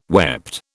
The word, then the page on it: wept: British English